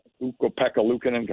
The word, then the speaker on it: Ukko-Pekka Luukkonen: Uh, Ukko-Pekka Luukkonen.